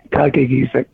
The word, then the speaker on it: Kakaygeesick: Well, the way I've been saying it and the way family says it is Kakaygeesick.